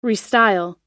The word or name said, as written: restyle